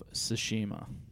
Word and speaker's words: Tsushima: Ghosts of Tsushima.